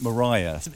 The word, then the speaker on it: Maria: Mariah Martin or the Murder in the Red Barn, and throughout that they pronounce it Mariah. It sounds a bit better than Maria, doesn't it, for kind of gothic purposes.